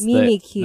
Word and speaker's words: Mimikyu: That's Mimikyu. – That's the proper pronunciation of that Pokemon. – Mimikyu? – Yes. – Reminds me of barbecue.